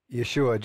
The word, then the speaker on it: Yeshua: What happened to you when you found, if I can say this right, Yeshua? – Yeshua, Jesus.